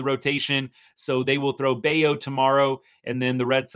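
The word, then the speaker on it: Bello: But Brian Bayo, sorry, I know I said the wrong pronunciation, can't keep track. But Bayo, he is going to be coming into this game not having pitched his greatest.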